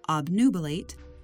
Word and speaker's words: obnubilate: That's spelled O-B-N-U-B-I-L-A-T-E. Note that this word has two valid pronunciations, obnubilate and obnubilate.